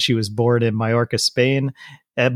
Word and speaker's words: Mallorca: Oh, that's Mallorca, right? That's how you say it.